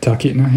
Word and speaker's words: Talkeetna: So Talkeetna is how you say it. Is that right? – Talkeetna, yeah.